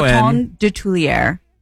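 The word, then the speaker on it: Coton de Tuléar: C-O-T-O-N. – Coton? – C-O-T-O-N. – Coton de Tulière. – How the hell do you spell that? – Of Tulière. D-E. T-U-L-E-A-R.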